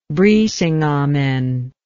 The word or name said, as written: Brisingamen